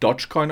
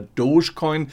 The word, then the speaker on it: Dogecoin: Der Dogecoin, nicht Dogecoin ausgesprochen, sondern der Dogecoin legt in diesen Tagen einen rasanten Höhenflug Ausflug hin und er wird zum Spekulationsobjekt.